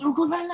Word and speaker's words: ukulele: Their names were ukulele. – Oh yeah. – Oh, it's ukulele. I get it. – Yeah. Ukulele. I said olele. What did I say? – I don't know.